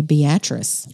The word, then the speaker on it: Beatrice: And no, I'm not mispronouncing it. We would call this Beatrice here in the state, the great state of Alabama. But in Nebraska, they say Beatrice.